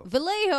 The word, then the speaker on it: Vallejo: He was out there living in Vallejo. – Oh, in Vallejo. – Vallejo, I said it right.